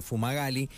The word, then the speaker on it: Fumagalli: Eh, y, y, bueno, nos estamos comunicando nosotros con el oficial inspector Ignacio Fumagali. Ignacio, buen día, te pronuncié bien el apellido, ¿cómo te va? – Hola, buen día, ¿cómo estás? Sí, sí, perfecto.